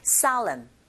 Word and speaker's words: solemn: 여기에 강세 주시고 그 다음에 SOLE에서 강세가 O에 있거든요. 철자 O에 강세가 같기 때문에 O보다는 I에 가깝게 발음이 들리고 그렇게 발음하시면 돼요. Solemn, solemn.